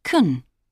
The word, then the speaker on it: can: In positive sentences and questions, can is pronounced kun. For example, can you use euros in Norway and Sweden? – I think you can use euros in Sweden, but not in Norway. In short answers, can is pronounced can.